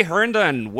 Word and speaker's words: Herndon: I don't know why that R&D trips a lot of people up. I've gotten Hendren, Herndon is really Herndon.